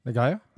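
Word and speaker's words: Nyong'o: You have Lupita Nagayo. – Nagayo, thank you.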